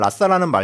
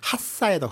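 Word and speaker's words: Lhasa: 일단 라싸라는 말도 이제 중국식 발음이고요. 티벳어로는 핫싸에 더 가까워요.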